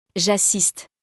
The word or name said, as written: j'assiste